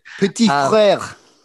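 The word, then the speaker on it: Petit-Frere: So I've picked Nicolas Petitfrère. My pronunciation is terrible, but it's French for little brother. Your pronunciation would be better than mine. – Petitfrère. – Petitfrère. – There you go.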